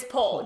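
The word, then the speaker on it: Pol: The thief character named P-O-L, I pronounce it. Is Paul, yes.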